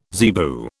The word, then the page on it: zebu: British English